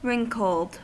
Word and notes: wrinkled: American English

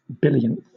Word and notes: billionth: British English

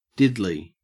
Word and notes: diddly: Australian English

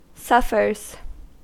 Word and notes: suffers: American English

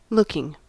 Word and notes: looking: American English

